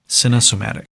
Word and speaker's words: Cinesomatic: Yeah. Cinesomatic, like somatics, and cine, like cinema. Cinesomatic.